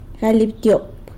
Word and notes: galy abtyop: sanlucasquiavini

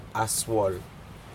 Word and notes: aswol: Aneityum

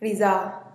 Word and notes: rizaa: TdVZ

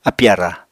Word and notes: apì-ara: Apatani

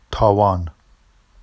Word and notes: tɑwɑn: Vafsi

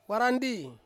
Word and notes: warandi: Ansus

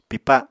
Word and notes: pipa: Apatani

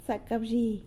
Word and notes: Zacawzhi!: sanlucasquiavini

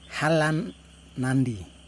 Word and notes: halamnandi: Yokoim

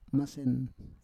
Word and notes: masen: Roon

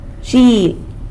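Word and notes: zhieb: San Lucas Quiaviní Zapotec

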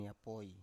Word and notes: yapoi: Biak

